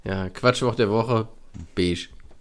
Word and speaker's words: Beige: Weil Beige schreibt man ja auch Beige. Das ergibt gar keinen Sinn, weil wieso schreibt man B-E-I-G-E und dann heißt es Beige? Wo ist das G hin? Wo kommt das SCH her?